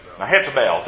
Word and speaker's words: Mehetabel: Say that for me, if you would, please. – Mehetabel, thank you. I was putting emphasis on the wrong syllable.